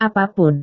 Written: apa pun

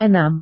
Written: enam

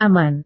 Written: aman

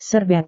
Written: serbet

